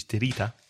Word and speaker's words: tirita: So not to be confused with plaster, which is tirita.